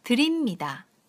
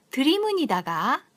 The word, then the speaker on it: 드립니다: 시마스, 드립니다. 드립니다. 드립니다를 잘못 발음하시면 드리문이다가 되어버리기 때문에 립을 높고 강하게 발음해 줍니다.